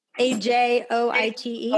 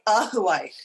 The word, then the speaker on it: Ajoite: How do you pronounce the next one? A, a joint. A J O I T E. Oh, Ajoite.